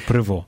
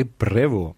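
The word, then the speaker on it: Prevost: Кардинал, будучи кардиналом, наш папа носив ім'я Роберта Франциска Прево, хоча пишеться Превости, але оскільки батько в нього був французом, тобто це французьке прізвище, по-французьки ці слова вимовляються саме так, так? Тобто Прево.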